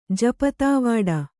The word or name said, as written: ಜಪತಾವಾಡ